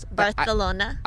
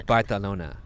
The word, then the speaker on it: Barcelona: Barcelona. – It's actually Barcelona.